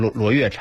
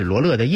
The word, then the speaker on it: 罗勒: 原来好比说他从别人那听了一个词儿，写的呢是这个欢乐的乐。然后呢，后头看的人呢就把它念成了音乐的乐，变成罗月茶。其实呢，他说的是罗勒的叶子，这样的话我就可以做九层塔了。